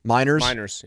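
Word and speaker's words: Meinerz: how do you say the guy in Denver, the guard? Quinn Miners? – Miners, yeah.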